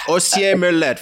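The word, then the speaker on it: Orcières-Merlette: Ossier-Merlet, from Sisteron to Ossier-Merlet, 157 kilometers.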